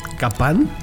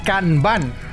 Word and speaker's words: Kanban: ¿Capán? Kanban. Kanban. K-A-N-B-A-N. Kanban. Kanban. Ah, mira, ok.